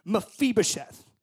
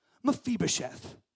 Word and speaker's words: Mephibosheth: Mephibosheth. Oh, it's like class. Like I say it, you say Spanish class. Okay, repeat after me. Mephibosheth.